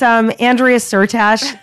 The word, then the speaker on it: Andrea Syrtash: It's Andrea Syrtash, A-N-D-R-E-A, S-Y-R-T-A-S-H.